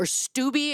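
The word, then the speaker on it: Steube: Representative William Stoib, or Stuby, excuse me, that is how it's pronounced.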